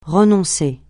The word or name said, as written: renoncer